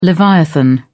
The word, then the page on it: leviathan: British English